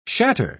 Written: shatter

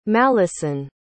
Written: mallison

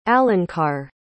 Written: alankar

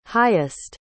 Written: highest

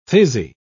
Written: fizzy

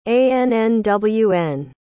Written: annwn

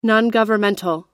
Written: nongovernmental

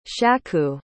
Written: shaku

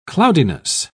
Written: cloudiness

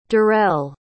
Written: durrell

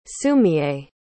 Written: sumiye

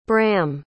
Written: bram

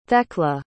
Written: thecla

